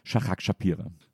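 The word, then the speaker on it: Shahak Shapira: Herzlich willkommen, Shahrag Shapira.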